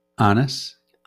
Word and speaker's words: Annas: How do you say that? – Annas.